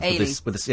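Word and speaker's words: Eilidh: Eli is Gaelic or Gallic for Helen. That's the one we couldn't pronounce. – Ailey. – Yeah, Ailey.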